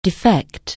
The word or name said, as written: defect